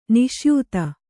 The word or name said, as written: ನಿಸ್ಯೂತ